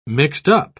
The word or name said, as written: mixed-up